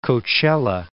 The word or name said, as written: Coachella